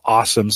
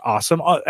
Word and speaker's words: Alxum: The company called Awesome, spelled A-L-X-U-M. They pronounce it just Awesome.